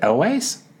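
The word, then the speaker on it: Elwes: How do you say his last name? Is it Elways? Yeah. Um, Ca- Cary L. E-L-W-E-S.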